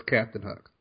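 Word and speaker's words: CPTN Hook: And so that's why it's spelled CPTN space Hook. – I don't think I could say Captain Hook. Captain. – I've never had a problem with someone pronouncing it. – Right. – They always know it's Captain Hook.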